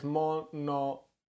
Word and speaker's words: Mononoke: Well, yeah, it's Mononoke.